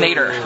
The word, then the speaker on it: nadir: which isn't like nay, but it's like nadir, which is actually pronounced nader, right? N-A-D-I-R, which means the lowest, the bottom, the bottom.